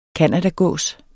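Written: CANADAGÅS

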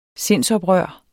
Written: sindsoprør

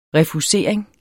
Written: refusering

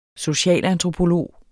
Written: socialantropolog

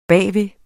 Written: bagved

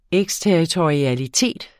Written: eksterritorialitet

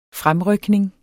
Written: fremrykning

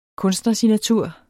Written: KUNSTNERSIGNATUR

